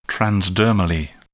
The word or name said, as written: transdermally